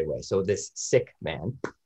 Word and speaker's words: Sikh: The crib notes is about 4 years ago in Kamloops, a Sikh gentleman. Now we say Sikh, it's actually pronounced Sikh. Okay.